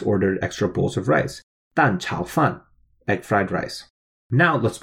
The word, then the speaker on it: 蛋炒饭: So that's pronounced 蛋炒饭. So that's a fourth tone, third tone, and another fourth tone. So that's the first fourth tone is D-A-N, 蛋, which means egg.